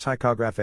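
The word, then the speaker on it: ptychography: In picography, pronounced ticograph,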